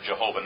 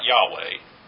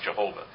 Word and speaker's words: Jehovah: And it's proper to pronounce it Jehovah, not Yahweh, in the English language. If you're Hebrew, that's different. But in English, it's properly pronounced Jehovah.